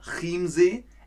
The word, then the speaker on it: Chiemsee: Achtung jetzt, das ist nicht Chiemsee oder Chiemsee, es ist Chiemsee. Manche CH-Laute im Deutschen spricht man wie K aus, wie zum Beispiel Christian oder Chiemsee.